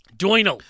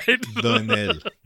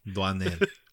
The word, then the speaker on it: Doinel: A French guy named Doinal. That can't be right. – Doinel. Doinel. Antoine Doinel.